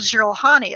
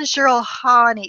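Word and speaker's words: Jiralhanae: And so Drew was like, it's the Jurahani. I was like, are you sure? So he's like, well, this is what these audiobooks say.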